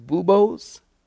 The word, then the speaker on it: buboes: Swollen lymph nodes, or I think it's pronounced buboes, B-U-B-O-E-S. Is that correct? Buboes. Have you heard of that? Is it buboes?